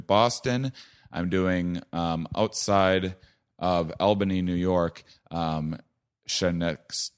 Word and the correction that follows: Schenectady: It's Schenectady.